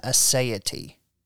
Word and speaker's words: aseity: So, number one, without taking up any more time, it is God's divine aseity.